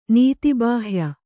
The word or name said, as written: ನೀತಿಬಾಹ್ಯ